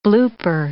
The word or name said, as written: blooper